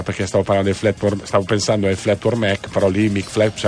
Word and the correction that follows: Fleetwood Mac: Fleetwood Mac.